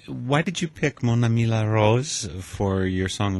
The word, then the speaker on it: Mon Ami La Rose: Forgive once again my pronunciation, but uh, I have Mon Ami La Rose by Natacha Atlas. – I want to say, first of all, your pronunciation was just fine. I think you did that R very well.